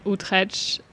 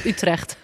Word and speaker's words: Utrecht: Okay, and then Rotterdam and Utrecht. And that's different. – Yeah. – We say Utrecht. – Utrecht. – Yeah, much better.